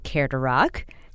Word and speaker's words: Care2Rock: And Karen, Care to Rock, and I want to pronounce it correctly, Care to Rock. That's how I say it in my mind, like a question, a dare.